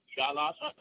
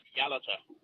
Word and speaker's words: Yalata: Just before I try and give an answer, you pronounce the town on the Nullarbor as Yalata. It's actually pronounced Yalata.